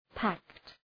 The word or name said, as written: pact